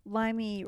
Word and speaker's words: Limey: Limey rhymes with slimy.